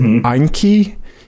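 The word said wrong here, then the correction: Enkei: Inky, Enki. – Yeah. – I would say Enki.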